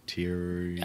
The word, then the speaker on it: Thieriot: The two vineyards that are touching it are also quite famous, Thieriot and Suma. – Thieriot. – I think it's T-H-E-I-R-I-O-T. Right. – But it's spelled different than it sounds. Yes. What was the second one? – And Summa, which is right. S-U-M-M-A.